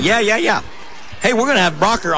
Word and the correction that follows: Broeker: Broker. – Broker. It's spelled B-R-O-E-K-E-R.